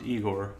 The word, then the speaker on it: Igor: It's pronounced Igor.